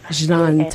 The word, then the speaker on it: Jnane: You pronounce it Jnane. – Jnane Tamsna, yeah.